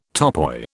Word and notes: topoi: British English

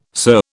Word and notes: sir: British English

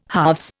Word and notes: halves: Indian

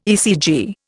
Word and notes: ECG: Indian

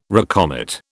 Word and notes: recommit: British English

